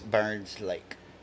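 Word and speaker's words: Byrnes Lake: And on the way back, we're stopping off in Baymanette, Alabama, at Byron's Lake, where they filmed Friday the 13th, Part 7. – I think that's Woo! Burns Lake. – It's Burns, Byron's. It's spelled B-Y-R-N-E-S.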